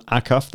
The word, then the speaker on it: Acuff: The guest, Eric's guest, was John Acuff. That's A-C-U-F-F. And John is spelled J-O-N.